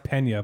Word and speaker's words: Pena: And I think it's pronounced Pena, but that's whatever. – Don't matter. We were saying Pena. – P-E-N-A? – I don't remember. No, I don't know. – It's Pena.